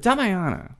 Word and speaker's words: Damiana: But Damiana, and that's the right pronunciation, right? Yeah.